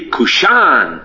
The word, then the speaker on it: Cushan: All this English anglicization. It should be Cushan. The accent's always on the last syllable.